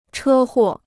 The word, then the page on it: 车祸: chē huò